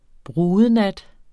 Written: brudenat

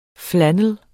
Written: flannel

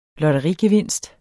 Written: lotterigevinst

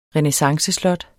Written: renæssanceslot

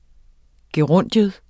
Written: gerundium

